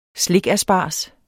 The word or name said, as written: slikasparges